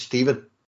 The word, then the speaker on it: Stefan: Um, I don't know how to pronounce his first name, so it's either S- Stefan. Uh, believe it, believe it or not, mate. I think it's Stephen. it's, just. – As... – yeah, I think it is. I, I think it's literally Stephen.